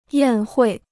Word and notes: 宴会: yàn huì

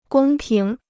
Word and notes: 公平: gōng píng

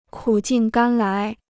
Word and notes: 苦尽甘来: kǔ jìn gān lái

